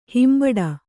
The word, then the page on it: ಹಿಂಬಡ: himbaḍa